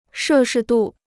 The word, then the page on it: 摄氏度: shè shì dù